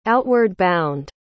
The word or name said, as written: outward-bound